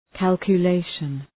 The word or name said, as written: calculation